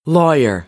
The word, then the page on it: lawyer: American English; ɑː